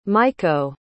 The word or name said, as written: maiko